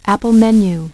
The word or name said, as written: Apple menu